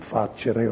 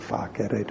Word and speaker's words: facere: It's on a lot of English words, and it comes from facere or facere, depending on how you want to pronounce the C, to make, and sace means holy, sanctus is holy.